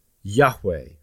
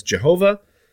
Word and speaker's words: Yahweh: Whenever you see in the Bible the word Lord, but in capital letters, all capital letters, L-O-R-D, that is indicating that it is the Hebrew word Yahweh. And I know some people want to pronounce Yahweh as Jehovah, and I'm not going to fuss at them about that. The short explanation is that I don't think that's the correct way to say that Hebrew word. I think that they took the vowel points for Adonai and put them over the letters for Yahweh.